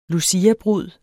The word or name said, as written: luciabrud